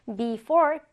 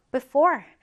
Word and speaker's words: before: 자, 지금 발음 기호가 두 가지가 있죠. 이렇게 되는 경우에 지난 시간에 선생님이 설명드렸던 것 같은데요. before, b로 발음하거나 아니면 요 발음 기호는 약간 짧은 모음이다라고 했어요. before, before 또는 before. 네, 두 가지 다 가능하다라는 거죠.